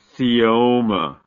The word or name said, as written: caeoma